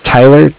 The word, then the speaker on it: Tyler: My name is Tyler, T-Y-L-E-R. – Mm-hmm. – And I'm calling with Cardiff. It's spelled C-A-R. D-I-F-F, as in Frank Frank.